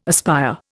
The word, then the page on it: aspire: Indian